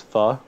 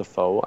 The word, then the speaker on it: pho: Or it's spelled pho. – Yeah, pho. – Yeah. – There's Love a place it. Around here called What the Pho, which until we knew it was pronounced pho, we're like, what the pho?